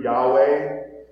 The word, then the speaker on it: Yahweh: In my translation, it's just given in the English the way it's pronounced in the Hebrew, Yahweh.